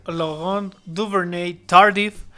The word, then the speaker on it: Laurent Duvernay-Tardif: Exacto, el nombre del jugador al que te estás refiriendo es Laurent Duvernay-Tardif.